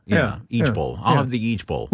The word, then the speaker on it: ijbol: There's also Eage Bowl. It's spelled I-J-B-O-L.